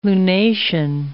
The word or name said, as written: lunation